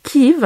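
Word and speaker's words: Kyiv: Quand j'étais en classe de 6e, devant ma carte du continent européen, car Kiev est la graphie et la prononciation ukrainienne.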